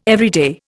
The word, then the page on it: everyday: Indian